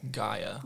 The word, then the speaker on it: Gaia: I don't know how to pronounce it. It's G-A-I-A? – Gaia. – Gaia, okay.